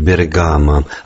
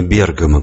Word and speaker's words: Бергамо: Как итальянцы вместо «Иван» говорят «Иван», так и русские обычно произносят «Бергамо», а не «Бергамо», как в оригинале.